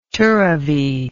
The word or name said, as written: tirrivee